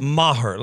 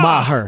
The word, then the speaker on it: Maher: Maher. Like, hey ma. Maher. Her. Okay. – I'm dating her. Ma. Okay. Her.